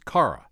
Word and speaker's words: Kara: It's spelled K-A-R-A, but it's pronounced Kara.